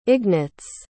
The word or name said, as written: Ignatz